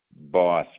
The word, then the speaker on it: Boss: Patrick Boss, you won't have that information though. – You say your last name was Moss? – Boss, BOSS.